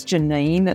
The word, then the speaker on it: Janyne: My name is Janyne. It's spelled J-A-N-Y-N-E.